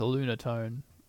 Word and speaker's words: Lunatone: Is it pronounced Lunatone? It's not Lunatone, or... – Pretty sure it's a Lunatone. – Lunatone? – Yeah. It's Lunatone and Solrock is its good friend.